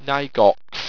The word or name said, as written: knygox